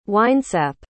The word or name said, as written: winesap